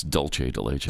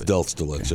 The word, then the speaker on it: dulce de leche: It's dulce de leche. – That's dulce de leche.